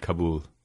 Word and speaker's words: Kabul: And is it pronounced Kabul or is it Kabul? – I pronounce it Kabul.